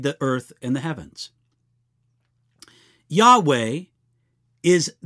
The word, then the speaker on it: Yahweh: In an older context, sometimes people pronounce that word Jehovah. You'll find that in many older English writings. But we've come to understand in the last few generations that really the more correct pronunciation of that holy name of God,